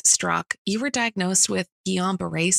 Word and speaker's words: Guillain-Barré: Great pronunciation of Guillain-Barré, by the way.